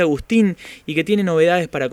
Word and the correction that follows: Agustine: Bueno, buenas tardes a toda la producción ahí del programa, y se pronuncia Agustine. – Ah, Agustine.